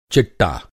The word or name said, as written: चिट्टा